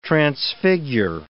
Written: transfigure

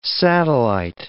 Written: satellite

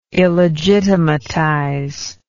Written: illegitimatize

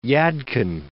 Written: Yadkin

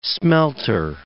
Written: smelter

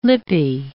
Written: lippy

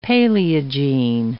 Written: Paleogene

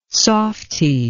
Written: softy

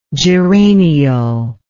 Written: geranial

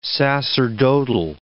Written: sacerdotal

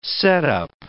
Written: setup

